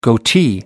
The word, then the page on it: goatee: American English